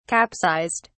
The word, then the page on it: capsized: British English